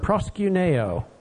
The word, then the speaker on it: proskuneo: The most common one is the Greek word proskuneo, P-R-O-S-K-U-N-E-O, proskuneo. Do I need to spell it again? Yeah, P-R-O-S-K-U-N-E-O.